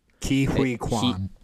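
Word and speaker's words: Ke Huy Quan: Ki-Hui Kwan.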